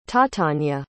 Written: tatania

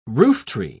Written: rooftree

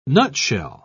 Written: nutshell

